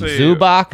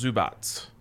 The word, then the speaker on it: Zubac: Love me some Zubac. – Pronounced Zubats. – Zubats, even better.